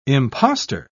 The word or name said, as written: imposter